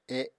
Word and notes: e: Apatani

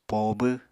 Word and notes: poobw: Apatani

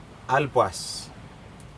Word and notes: alPas: Aneityum

